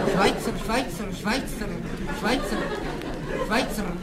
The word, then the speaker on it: Schweitzer: No to se vyslovuje Švajce.